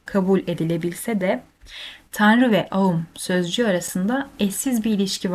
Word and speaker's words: Aum: Birlikte aum diye telaffuz edilen üç harf a, u ve m tüm olası seslerin genel sembolleridir.